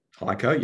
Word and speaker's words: HEICO: Yeah, How Is that how you pronounce it? – HEICO. Yep.